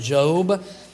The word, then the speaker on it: Job: And so there it is, the book of Job, some people would say, but it's pronounced Job.